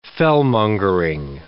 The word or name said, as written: fellmongering